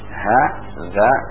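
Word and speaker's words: haza (هذا): Perhatikan nulisnya ha za kemudian alif. Ya, dibacanya haza. Ha panjang za. Haza.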